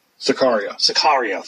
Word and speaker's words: Sicario: I watched a trailer for, I'm going to pronounce it so wrong, Sicario.